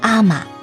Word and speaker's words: 海女: 海人是在日本近海以潜水的方式捕鱼以及采集鲍鱼、珍珠等等为生的人，男性称为海士，女性则称为海女，他们读成阿玛。